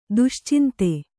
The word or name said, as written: ದುಶ್ಚಿಂತೆ